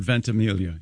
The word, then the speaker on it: Ventimiglia: Is it Ventimiglia? – Does he go with the soft G or does he do the Mike Birbiglia thing? – I have heard the soft G. – I've heard Ventimiglia, yes.